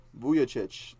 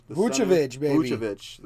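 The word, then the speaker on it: Vučević: And Nikola Vucevic. – Vucevic, the center for Orlando Magic.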